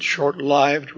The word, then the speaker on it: short-lived: By the way, you may notice I pronounce it short-lived rather than lived. I think that should be correct because lived here in this sense derives from the word life with a long I.